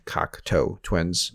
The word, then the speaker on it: Cocteau Twins: You notice how I said it correctly? Cocteau Twins.